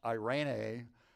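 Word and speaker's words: eirene (εἰρήνη): Peace is used in the New Testament is from the Greek word, and it's pronounced eirene, and it means security, safety, prosperity.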